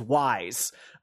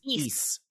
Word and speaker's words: Ys: By the way, there are a lot of people that think this is called WISE, because it's capital Y, lowercase s, and so there's a lot of people that call this series WISE. So it's Ys. – Yeah. So even I know that pronunciation.